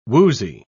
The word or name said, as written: woozy